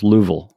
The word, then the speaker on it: Louisville: It's you got to make sure it's just Louisville. Like it does. There's no S in there whatsoever. – Yeah. Well, I have a friend who lives in Louisville, and he said it's two syllables. Louisville, is the way he says it.